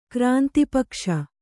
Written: ಕ್ರಾಂತಿಪಕ್ಷ

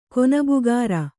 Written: ಕೊನಬುಗಾರ

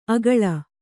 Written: ಅಕಳ

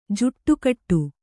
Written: ಜೋಡುಗೂಡು

